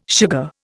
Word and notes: sugar: Indian

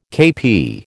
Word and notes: KP: American English

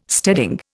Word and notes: steading: Indian

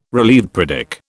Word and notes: relieved: British English

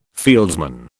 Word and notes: fieldsman: British English

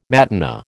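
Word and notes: matinée: American English

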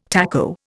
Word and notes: taco: Indian

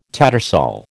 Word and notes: tattersall: American English

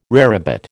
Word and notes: rarebit: American English

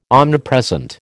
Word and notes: omnipresent: American English

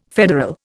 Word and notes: federal: Indian

